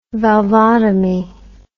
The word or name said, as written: valvotomy